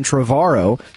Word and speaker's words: Trevorrow: And now there's a director who directed Safety Not Guaranteed, and he also directed Jurassic World, a guy named Colin Trevorrow. He told me on the phone that his last name rhymes with tomorrow, because I thought it was Trevorrow.